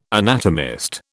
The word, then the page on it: anatomist: British English